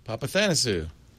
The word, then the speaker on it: Papathanasiou: Cellebrite engineer Lee, and here's a name, Papathanasiou, Papathanasiou. – Yeah, that's Greek, Papathanasiou. – Papathanasiou.